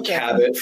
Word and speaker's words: Cabot: Sometimes when I'm feeling sophisticated, I go with the silent T and just Cabot Phillips. – Cabot. – We'll stick with Cabot for now.